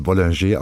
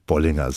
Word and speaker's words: Bollinger: Sie haben auch gerade gesagt, natürlich in Frankreich spricht man das Bollinger aus, aber ich habe mich mal kundig gemacht, man darf Bollinger sagen. Auch für einen Franzosen ist das korrekt ausgesprochen. Ja.